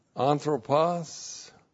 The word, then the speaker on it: anthropos: And then we have anthropos, the generic term for mankind, A-N-T-H-R, long O-P-O-S.